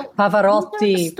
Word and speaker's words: Pavarotti: You know, Pavarotti. – Yeah, there you go with the Italian pronunciation.